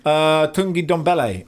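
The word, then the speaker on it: Tanguy Ndombele: Look, they've broken their transfer record to sign Tungi Ndombele, which I've pronounced about 10 different ways, but I believe that is the correct way.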